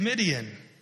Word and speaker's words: Midian: What was the day of Midian? Well, it's easy to remember because it rhymes with Gideon.